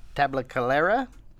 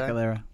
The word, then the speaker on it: Tabacalera: A deal that will include Tabacalera. I said that right this time. – Tabacalera.